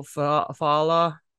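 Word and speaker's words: Fallah: And there's a quote from try this name, Bilal Fala. I don't know. He's one of the directors. – It's not Spanish, but it's not Spanish. So you pronounce both L's.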